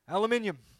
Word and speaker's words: aluminium: I just said aluminum is an American, didn't I? Aluminium. There we go, we're in Australia.